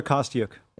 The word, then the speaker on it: Kostyuk: Kostyuk? – Marta Kostyuk.